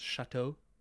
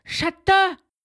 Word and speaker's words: Chatot: Chatot. – It's Chatot. – I believe it's actually pronounced Chateau. – It's Chata.